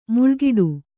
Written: ಮುಳ್ಳುಗಿಡ

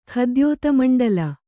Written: ಖದ್ಯೋತಮಂಡಲ

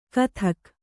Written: ಘಾತುಕ

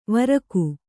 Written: ವೃಕ್ಕೆ

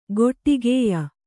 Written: ಗೊಟ್ಟಿಗೆಯ್

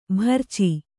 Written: ಭರ್ಚಿ

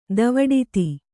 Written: ದೇವೆಡಿತ್ತಿ

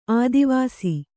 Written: ಆದಿವಸು

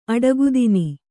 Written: ಅಡಗುತಿನ್

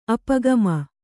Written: ಅಪಗಾಮಿ

